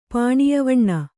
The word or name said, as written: ಪಾಣಿಯವಣ್ಣ